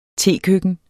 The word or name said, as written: tekøkken